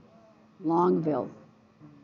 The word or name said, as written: Longville